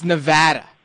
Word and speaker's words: Nevada: Just had to make sure I got that Nevada right. It's Nevada. Jeez. – Oh yeah, it's Nevada. I notice out here in the East, people pronounce it a lot differently, but I'm willing to overlook it.